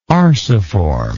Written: arciform

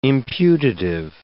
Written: imputative

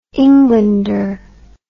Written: englander

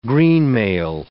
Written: greenmail

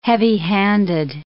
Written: heavy-handed

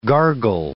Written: gargle

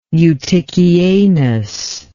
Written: Eutychianus

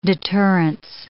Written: deterrence

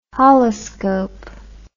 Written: holoscope

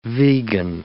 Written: vegan

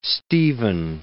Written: Stephen

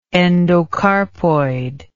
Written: endocarpoid

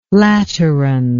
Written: lateran

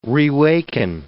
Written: rewaken

